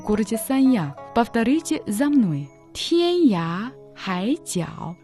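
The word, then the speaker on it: Тянья Хай Цяо (天涯海角): Тянь читается первым тоном, означает «небо». «Я» читается во втором тоне, значит «край». «Хай» – третьим тоном, значит «море». «Тяо» – в третьем тоне, означает «угол», «конец».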